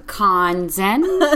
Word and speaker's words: Konzen: So my name is Jessica Konzen, and how do you say it? – Konzen. Okay.